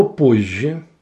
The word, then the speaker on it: позже: Слово «позже» в транскрипции будет записано так. «ПОЖ» – мягкий знак, «ЖЕ» – «позже». Обратите внимание, согласная «З» перешла в согласную «Ж».